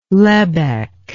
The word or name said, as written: lebbek